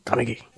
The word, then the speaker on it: Kamiki: Eh, och sen så har de en annan japansk whisky som heter Kamiki. Eller Kamiki. Man har förstått med japanska, man ska inte ha någon betoning på någon, så Kamiki.